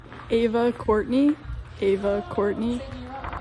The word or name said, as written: Ava Courtney